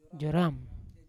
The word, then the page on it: joram: Yawa